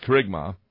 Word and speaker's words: kerygma: It's pronounced kerygma.